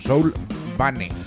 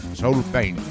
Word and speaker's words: Soulbane: No sé si lo pronuncia muy bien, parece que haya dicho pena espiritual, no, Soulbane, Soulbane. Tema extenso,